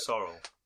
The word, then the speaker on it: Sorrel: Sorrel. – Sorrel. – Okay, cool. – It's like a plant. There's a plant called Sorrel.